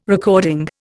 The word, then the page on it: recording: Indian